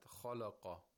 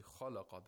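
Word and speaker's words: Khalaqa (خَلَقَ): So we have to make sure that the lam is not pronounced with tafkhim because it's easier to do that. Khalaqa. You could say khalaqa, but you should say khalaqa.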